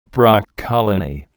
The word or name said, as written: broccolini